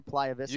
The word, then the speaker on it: Playa Vista: Am I pronouncing that right, Playa Vista? – You got it.